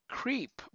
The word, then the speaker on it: Kreip: His name is Creep, but it's spelled like K-R-E-I-P.